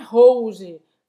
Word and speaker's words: rouge: Faz esse o u Em francês tem simplesmente o som de U em português. Então super fácil. Você não vai falar rouge, não vai falar rouge, não pronuncia esse E no final. Você vai terminar nesse J, j aqui do G.